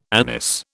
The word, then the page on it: anise: British English